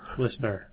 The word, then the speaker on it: LISNR: Listener, yes.